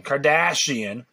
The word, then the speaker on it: Kardashian: In 2015, Kim Kardashian, okay, that's pronounced her name, Kardashian,